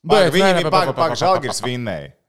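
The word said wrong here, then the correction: Žalgiris: Nē, žaļgiris, kā teica Lašausks. Pareizi jāizrunā, so žaļgiris.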